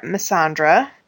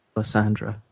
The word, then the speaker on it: Melisandre: Stannis arrives with his flag bearer, Missandra. Wait, how are we pronouncing this? – Melisandre is how I say it. – Yeah? – Pronounce it. I've heard Melisandre.